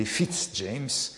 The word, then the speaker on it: Fitz-James: Mon grand-oncle Fitz-Jam n'avait rien qui étonnait, car on sait que les Fitz-James proclament volontiers qu'ils sont de grands seigneurs français et ne veulent pas qu'on prononce leur nom à l'anglaise. Souvenez-vous, plus tard, Fitz-Jam m'attend.